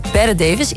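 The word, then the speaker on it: Bette: Ja, dit was Kim Carnes met het nummer over de ogen van actrice Betty Davis, inderdaad. Actrice hoe spreek je dit uit? Betty? – Betty Davis.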